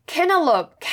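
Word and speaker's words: cantaloupe: 그래가지고 엄청 땀 뻘뻘 흘리면서 어찌어찌 구글로 검색을 해서 아 알고 보니 이게 T가 여는 발음이 돼서 캐널럽이 된 거였구나.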